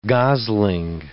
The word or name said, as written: gosling